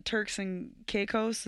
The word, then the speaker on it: Turks and Caicos: Or, like, how about the family got stuck in Turks and Caicos?